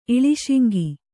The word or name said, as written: ಇಳಿಶಿಂಗಿ